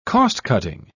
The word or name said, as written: cost-cutting